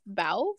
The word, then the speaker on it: Bao: Bao, from when I seen it on, I was at the drive-in when I saw that one.